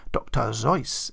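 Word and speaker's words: Dr. Seuss: Most readers know him better as Dr. Zeus, or Dr. Zeus, as it should be pronounced.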